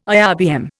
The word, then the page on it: IRBM: Indian